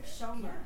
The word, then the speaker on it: Schoemer: Am I saying that right? – Schoemer. – Schoemer. I apologize.